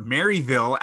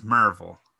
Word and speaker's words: Maryville: What is your favorite thing about Merville or Maryville as it is spelled? But I guess in Tennessee, it's Merville.